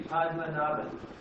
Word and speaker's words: Padmanabhan: I always said Padmanabhan, but then I was told it's Padmanabhan, and well, how do you say it? – Don't mind. There's no expert. No expert. – It should be Padmanabhan. – Padmanabhan. Okay. It's official.